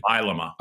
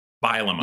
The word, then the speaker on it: Bielema: Uh, Matt is worried, and I think rightly so, about the beginning um, because you got to go into Illinois and the refurbished Bielema machine. Bielema. Uh, and then come home, huh? Bile them You said, no, no, stop that. You're mispronouncing it on purpose.